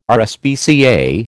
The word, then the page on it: RSPCA: American English